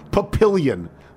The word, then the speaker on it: Papillion: Papillon is pronounced the way any self-respecting Midwesterner would say a misspelled French word, papillion.